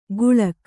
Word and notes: ಗುಳಕ್: Kannada

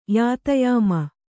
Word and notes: ಯಾತಯಾಮ: yātāyāma; Kannada

